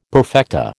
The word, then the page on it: perfecta: American English